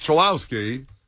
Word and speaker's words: Chulowski: Yikes, it's Chulowski. Am I saying that right? – You're 100% right. – Because I'm in my head because he said it wrong. – I know it would seem like we should listen to the enunciation and the pronunciation of the commissioner of Major League Baseball. – Yeah. – But he said his name wrong.